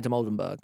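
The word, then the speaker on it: Dimoldenberg: I'm kind of surprised that Amelia de Molden, I don't know how to say her surname. – Amelia de Moldenberg. – Amelia de Moldenberg.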